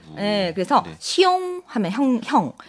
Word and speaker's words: 兄: 그래서 형 하면은 한자로는 시옹, 시옹 하고 발음하고 시옹.